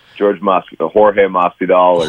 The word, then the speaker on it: Jorge Masvidal: Masvidal, is that how you pronounce it? George Masvidal? – Jorge Masvidal.